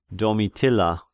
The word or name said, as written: Domitilla